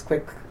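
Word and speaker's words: Qik: So I'll post a little link to his Quick. Is it called Quick? – Or Blip. – It's Q-I-K, but is that how you say it? – Yeah.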